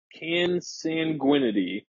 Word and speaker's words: consanguinity: It's uh, consanguinity.